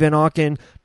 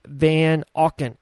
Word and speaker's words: Van Auken: My name is Dave Van Auken. John Rafford, the professor, was on the podcast earlier. His Instagram handle, John_Nos, N-O-S. My Instagram handle is at David Van Auken, A-U-K-E-N.